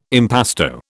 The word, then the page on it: impasto: British English